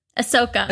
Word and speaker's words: Ahsoka: Wait, is that, am I saying her name right? Ahsoka? – Ahsoka.